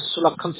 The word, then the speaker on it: Sulakhan: My name is Sulakhan Singh.